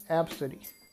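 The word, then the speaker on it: Abcde: And the reason she took a picture is because the passenger's name is spelled A-B-D-C-E. Again, that's A-B-C-D-E. And the mother said the name is pronounced Absidy.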